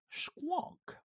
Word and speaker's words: squonk: is known as a squonk. You have to say it like that, I promise.